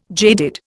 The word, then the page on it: jaded: Indian